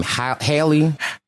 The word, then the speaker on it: Halle: I'm not a huge like Chloe and how you say her name, Halle.